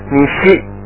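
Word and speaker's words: nishi (西): The next is the west is pronounced nishi, nishi.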